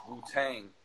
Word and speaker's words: Wu-Tang: I love that you texted me that night, and like it made me like even the phrase Wu-Tang. Like it hits that first syllable so hard.